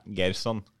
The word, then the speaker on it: Gerson: Ja, Gerson.